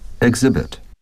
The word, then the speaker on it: exhibit: Exhibit. – Exhibit. – Exhibit 这个单词的重音很重要，大家不要忘记了，不要把它读成 exhibit,、 exhibit.